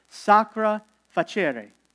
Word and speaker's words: sacra facere: Sacra facere. You like my Latin? I pronounce it with an Italian accent, just in case you want to know.